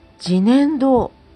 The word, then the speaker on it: 自然堂: 自然堂と書いて自然堂と読むそうなんですねこちらに伺わせていただいた時のことをちょっとお話ししたいと思います。